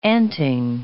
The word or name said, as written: anting